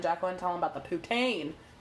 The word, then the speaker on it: poutine: Make sure you say it right. – I can't say it right. – Yeah, you can. – I'm not even going to try. – Poutine. – Anyway. – Not Pouton.